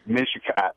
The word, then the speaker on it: Mishicot: Is it Mishicot? Mishicot? – Mishicot.